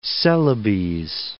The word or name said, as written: Celebes